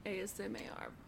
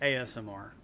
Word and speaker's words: ASMR: I have some solid ASMR. Am I saying it right? – You are not. – How do you say there's it? One, you're adding an extra A in there. It's just ASMR.